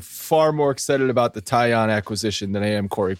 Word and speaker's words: Taillon: Jameson Taillon, after 15 minutes of Googling to figure out how to pronounce his last name, I am 99.9% sure it's Taillon, because I found a Sportsnet Pittsburgh broadcast that introduced the man.